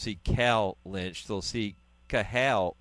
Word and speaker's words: Cathal: and it looks like Cathal. I used to mispronounce it all the time, and I think a lot of people did. The T is actually silent in his name,